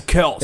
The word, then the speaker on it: Kelce: It's going to be he and Kels, and that's it. – Kelsey, I'm sorry. – Yeah, come on, man. Get the name right. – Travis Kelce. – It sounds like a girl's name, so I don't like to say it.